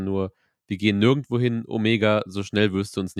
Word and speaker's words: Omega: Und ähm, Omega hat ein bisschen Angst davor. Omega, nachdrücklich richtig ausgesprochen,